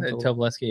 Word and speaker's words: Tobolowsky: and had Stephen Tobolowsky. Is that how you pronounce his name? – Tobolowsky. Yeah, he was a professor teaching he said it was an unsolvable question.